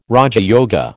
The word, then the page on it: raja yoga: American English